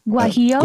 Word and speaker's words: guajillo: It's got the cheese wrap flour tortilla topped with traditional guajillo. Did I say that right? – Guajillo. – Guajillo. That's it. Guajillo. Thank you, for always correcting me.